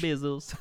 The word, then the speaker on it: Bezos: Bezos. – Bezos? – Why'd I say his name like Spanish? – Bezos. – Hola, mi amo Bezos. – Hola. – Okay, the American Bezos.